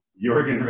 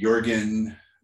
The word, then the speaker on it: Jørgen: However, our guest today, Jørgen, am I saying that right? It's a Norwegian name. – Pretty close. – Jørgen Rasmussen is a powerful agent for personal change.